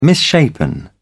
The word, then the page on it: misshapen: British English